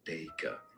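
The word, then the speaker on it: Dacre: In British English, it is said as Daker. – Daker. – Stress on the first syllable or.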